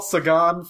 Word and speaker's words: Sagan: Thanks, Carl Sagan. Is that how you pronounce his name? – Yes, Carl Sagan from the, you know, the wondrous play Mrs. Saigon.